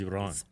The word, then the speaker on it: Iran: So I've been mispronouncing that my whole life. It's Iran. – Iran. Yeah. You said it right. – Iran. I can roll my R's.